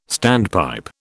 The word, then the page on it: standpipe: British English